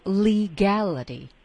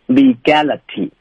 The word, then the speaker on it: legality: 合法性，大家注意它的读音，叫做 legality。 – legality，对。有一个 er 的小音哈，对 legality 重音在 gality 上面，哎，这个名词是 legality，什么意思啊？